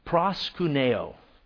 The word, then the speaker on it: proskuneo: it's a word that's pronounced proskuneo.